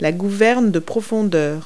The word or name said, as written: elevator